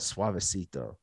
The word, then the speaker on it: Suavecito: It's called Suavecito. And she bought it because it rhymes with Despacito.